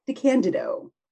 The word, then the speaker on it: DeCandido: I'm Lisa Meese, and today I'm talking to Keith DeCandido.